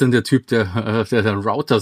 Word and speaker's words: Router: In Deutschland sagt jeder Router, gell? Das heißt eigentlich Router.